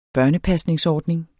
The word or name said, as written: børnepasningsordning